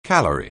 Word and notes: calorie: anglais

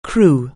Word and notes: crow: anglais

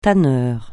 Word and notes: tanneur: francais

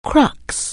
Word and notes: crux: anglais

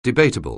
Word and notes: debatable: anglais

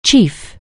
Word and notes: chief: English

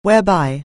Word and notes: whereby: anglais

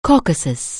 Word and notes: Caucasus: anglais

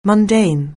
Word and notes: mundane: anglais

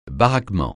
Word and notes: baraquement: francais

